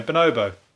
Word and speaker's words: Bonobo: I don't know how to say his name. Bonobo. Same as the monkey, isn't it? Bonobo.